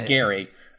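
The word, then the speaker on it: Gerry: Or it's actually Gary. I'm pretty sure that the politician, it's pronounced Gary, but that's how you got gerrymandering.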